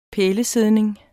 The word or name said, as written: pælesidning